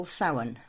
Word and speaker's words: Samhain: Then the next one we have, we call Samhain, which is spelled S-A-M-H-I-A-N, and it's Halloween.